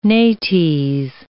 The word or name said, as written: nates